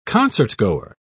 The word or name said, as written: concertgoer